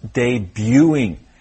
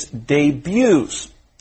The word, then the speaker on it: debut: Again, the T is silent, so you should read it as if the T is not there. The product debuted 5 years ago. – Great. And by the same reasoning, the progressive form should be pronounced debuting. And the plural form is debuts. You pronounce all of them without the T sound.